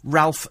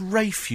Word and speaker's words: Ralph: And I'm shouting at the television, it's Rafe, you stupid woman. I know it's spelt Ralph, but surely you can't be that dim that you don't know it's pronounced Rafe.